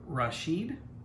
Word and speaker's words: Rashid: was found in the Egyptian port city of Rashid. Is that how you'd pronounce that? – I think so, yeah.